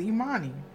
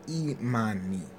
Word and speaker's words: Imani: Imani. – Why are you pronouncing it like that? It's Imani. – Imani.